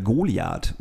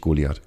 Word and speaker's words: Goliath: Und dann kann ich auch nur sagen, deshalb wird Goliath ausgesprochen. – Mhm. – Für dich. Nicht Goliath, Goliath. – Goliath. Okay, Goliath. Alles klar.